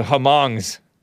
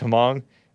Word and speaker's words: Hmong: Gran Torino, the Hmongs. And I know it's not supposed to be pronounced Hmong. It's like Hmong.